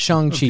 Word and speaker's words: Shang-Chi: They're not Shang-Chi.